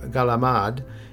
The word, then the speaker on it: calamari: Another seafood touchpoint that can be enjoyed in a variety of delicious ways on Christmas Eve, calamari, or galamad, as it's pronounced in some families, consists of the rings and tentacles of squid.